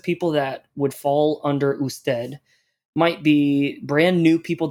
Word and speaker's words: usted: Usted.